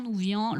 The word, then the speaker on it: Nouvian: donc de l'association Claire Nouvian, ça s'écrit N-O-U-V-I-A-N. n o u v i a n et je trouve euh, ben, que ça résume extrêmement mm.